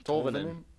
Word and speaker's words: Tolvanen: They up front, you're looking at Brad Richardson, Mikael Granlund, and Eric Haula are three UFAs, as well as Eli Tolvanen and Matthew Oliver as RFAs. – They'll definitely sign Tolvanen. I believe that's how it's pronounced. – Tolvanen. Okay. All right. – It's Tolvanen.